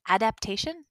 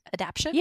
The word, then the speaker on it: adaptation: How do you say adaptation? Adaption? – Yeah. It's not adaption. Adaptation.